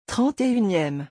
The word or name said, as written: 31eme